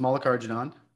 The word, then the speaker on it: Mallikarjunan: So, my name's Sam, last name pronounced Mallikarjunan.